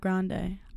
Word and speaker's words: Grande: Ariana Grande.